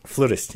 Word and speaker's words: flutist: Your wife is a, how do you say it, flutist, flautist? – Flutist, yes. – Flutist. Is flautist like completely wrong?